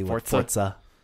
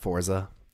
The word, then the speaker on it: Forza: I really appreciate you pronounce it correctly. Forza, not Forza.